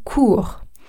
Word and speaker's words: cours: Si vous voulez dire a course, like in English, on dit un cours And yes, I know there is an S at the end, but you do not pronounce it. Okay? It's a silent S. So we say un cours.